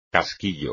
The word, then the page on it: casquillo: espagnol